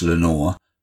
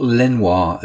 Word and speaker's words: Lenoir: It is pronounced Lenore, not Lenoir, as John Mayall sings in the two tribute songs to J.B., written after his untimely death from complications after a car accident in 1967.